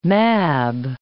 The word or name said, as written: Mab